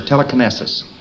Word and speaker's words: telekinesis: But you don't find us using words like telekinesis, or telekinesis, that's the proper pronunciation of it.